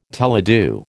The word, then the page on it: teledu: American English